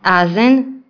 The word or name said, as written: azno